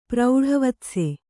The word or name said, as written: ಪ್ರೌಢವತ್ಸೆ